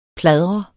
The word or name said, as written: pladre